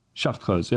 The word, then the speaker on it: Chartreuse: Well, I guess today's episode is brought to you by Chartreuse. – Is that how you say that? – Chartreuse. Yes, French.